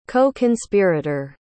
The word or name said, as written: co-conspirator